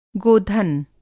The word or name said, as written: गोधन